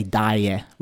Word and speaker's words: daje: But there's a more modern like dialect way that they say it, I think in Rome, where they say die, which is spelled D-A-J-E, die, which means like an immediate like right this second, like let's go, like right now.